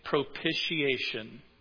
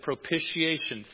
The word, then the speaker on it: propitiation: Well, somebody looked it up and said it's propitiation. Think initiation, right? Initiation. It sounds just like that.